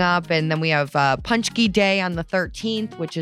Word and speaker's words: Paczki: Hey, so yeah, tell us about Paczki.